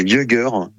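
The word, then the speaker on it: Jugger: On avait le yogur.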